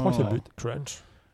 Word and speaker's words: Kerrunch: Alors, Crunch, ça s'écrit K-E-R-R-U-N-C-H.